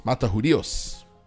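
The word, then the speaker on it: Matajudíos: I guess it's called, or it's pronounced, Matajuidos. Matajudios.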